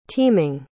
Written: teaming

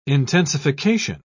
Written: intensification

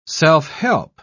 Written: self-help

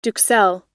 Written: duxelles

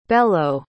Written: bello

